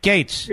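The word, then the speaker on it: Gaetz: And I'm supposed to get upset about this Mike Getz? How do you pronounce it? Gates.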